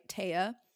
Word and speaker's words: Taya: So my name, right, Taya. The pronunciation actually comes from a Norse goddess. – Ooh, did not know that. – Dorothea. – Is it T-H-E-A? – Yeah, it is.